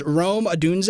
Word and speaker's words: Rome Odunze: Green Bay Packers, Joe Alt, Notre Dame offensive tackle, Tennessee Titans, Rome Odunze.